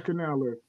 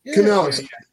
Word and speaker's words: Canales: The coach of Carolina is Canales. – Canales. I'm sorry. You're right. I mispronounced it.